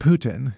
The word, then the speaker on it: poutine: Apparently, in French, Poutine is pronounced Putin.